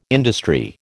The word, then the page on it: industry: American English